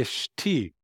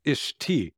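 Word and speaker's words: Ishti (אשתי): So I spelled it I S H T E E, Ishti, because that's how it's pronounced. So it's Ishti.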